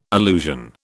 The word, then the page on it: allusion: British English